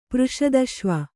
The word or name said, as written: ಪೃಷದಶ್ವ